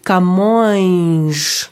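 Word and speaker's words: Camões: ça, ça euh, ça s'écrit C-A-M-O tilde O-E-S, se dit camange. Donc on a le I. Le O-E se dit OI.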